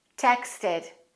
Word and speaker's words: texted: But in the word texted, the ED is pronounced as a separate syllable, texted. And do you know why?